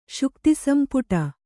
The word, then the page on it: ಶುಕ್ತಿಸಂಪುಟ: kannada